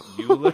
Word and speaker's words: Euler: Euler, not Euler. – It's Euler, but I can't. I can't wrap. Oh, that just doesn't even make sense. Like, I can get behind. Oh, there's the I see it. The pronunciation guide. O Y is emphasis with the dash. L A R.